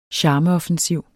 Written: CHARMEOFFENSIV

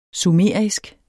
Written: sumerisk

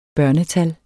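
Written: børnetal

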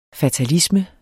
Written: fatalisme

